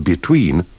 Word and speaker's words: between: In these dialogues, remember to pronounce the first E of below, beneath, beside, and between with the sound of the I in sit.